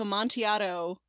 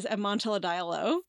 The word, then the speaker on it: Amontillado: And I really liked it, but I kept pronouncing it in my head as Amontilladiello. – Oh, no. – Cannot explain why I had these extra syllables in there.